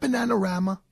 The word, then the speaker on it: Bananarama: I think the reason why you played that song was that Bananarama? – Yes, it was.